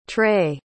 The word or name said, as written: trae